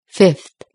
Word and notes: fifth: British English

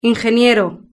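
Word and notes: ingeniero: Spanish (Spain)

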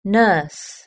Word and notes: nurse: British English

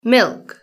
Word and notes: milk: British English

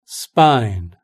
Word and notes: spine: British English

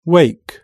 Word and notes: wake: British English

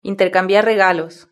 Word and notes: intercambiar regalos: Spain Spanish

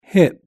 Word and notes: hip: British English

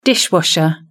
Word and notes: dishwasher: British English

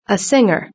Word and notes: a singer: British English

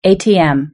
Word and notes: atm: British English

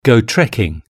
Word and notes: go trecking: British English

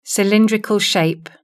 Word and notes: cylindrical shape: British English

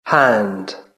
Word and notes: hand: British English

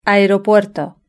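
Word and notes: aeropuerto: European Spanish

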